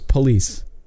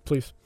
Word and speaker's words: police: There's two different syllables. It's police. Not police. – Please. – You're saying it wrong, dude.